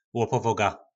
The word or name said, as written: lopovoga